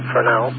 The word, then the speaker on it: Fresnel: And the other one was the Fresnel zone, and that's the proper pronunciation, Fresnel, with the emphasis on the second syllable.